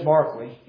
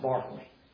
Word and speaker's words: Berkeley: It looks like it's George Berkeley, yeah. His name looks like Berkeley, but it's pronounced Barkley.